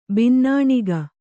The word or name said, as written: ಬಿನ್ನಣಿಗೆ